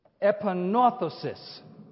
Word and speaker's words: epanorthosis: The word is pronounced epanorthosis. Epanorthosis. It's phonetically spelled E-P-A-N-O-R-T-H-O-S-I-S. It sounds complicated, but that's the word. E-P-A-N-O-R-T-H-O-S-I-S.